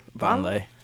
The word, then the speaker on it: Vonleh: Vonleh.